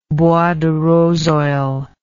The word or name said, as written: bois de rose oil